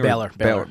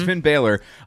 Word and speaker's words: Balor: Finn Balor, or is it Balor? Is that how you say his name? – Balor. – Mm-hmm. It's Finn Balor.